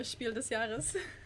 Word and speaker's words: Spiel des Jahres: I mean, considering this year's choice for the Spiel der Jahres, I can't really throw shade at the Deutsche Spielepreis. Sorry, I was just thinking about how nobody ever pronounces the S in Spiel des Jahres.